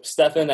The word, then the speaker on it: Stefan: So Stefan, S-T-E-F-A-N, Paul is my middle name, and Georgi is G-E-O-R-G-I.